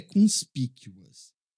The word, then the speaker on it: conspicuous: Essa palavra se pronuncia conspicuous.